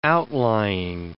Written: outlying

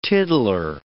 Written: tiddler

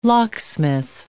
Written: locksmith

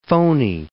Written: phoney

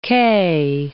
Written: quai

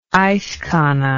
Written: icekhana

